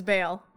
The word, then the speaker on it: Baal: And then someone had to correct me, and I was just like, oh, because it's spelled B A A L. So I thought, okay, it's Ball, like Ba. but now I know it's Bale.